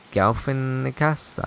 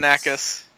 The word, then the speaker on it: Galifianakis: Zach Galifianakis, I know I said that wrong, as Chip Baskets. – Galifianakis.